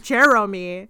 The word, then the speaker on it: Geromy: Yeah, I pronounce it like Jeremy. – Jeremy. – G-E-R-O-M-Y.